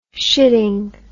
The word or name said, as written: shitting